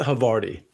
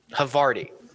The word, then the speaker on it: Havarti: Victor Hovayarty. Hovayarty. Did I say that right? Does that sound right to you? – I think it's a Havarti. – Havarti. – Yeah. – Yeah, that would make more sense.